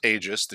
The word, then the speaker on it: Aegis: Aegis. That is pronounced Aegis, the Shield of Chaos.